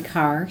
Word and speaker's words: Carr: Yes, Joanne Carr, C-A-R-R.